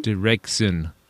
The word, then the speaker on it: Deraikzun: From, and I might need some help pronouncing this guy's name, from D-E-R-A-I-K-Z-U-N. – Deraxun? – Deraxun? – Deraxun.